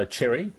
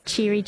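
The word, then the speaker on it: Chery: So Cherry, not Cheery.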